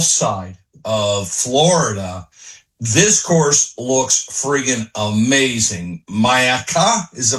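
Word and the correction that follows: Myakka: Am I saying that right? – Yeah, Mayaka? – Miyaka. Yes, Miyaka.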